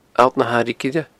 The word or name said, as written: Allt na h-Airighe Gile